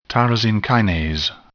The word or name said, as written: tyrosine kinase